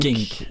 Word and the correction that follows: Genk: Genk yep. Eh, uttalet eh, reserverar jag mig för.